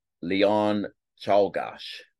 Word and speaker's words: Czolgosz: Leon Czolgosz. That's his name. You pronounce it Leon Czolgosz.